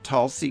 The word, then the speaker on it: Tulsi: Tulsi Gabbard is Tulsi. Geez. Like Tulsa.